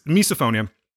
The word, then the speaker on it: misophonia: So misophonia, I wanted to make sure that I was saying it correctly. Yes, it's misophonia.